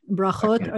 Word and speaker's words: brachot: Berakoth prayers, am I saying it correctly? – So it would be bracha is a blessing. Brachot are the, is the plural.